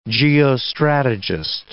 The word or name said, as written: geostrategist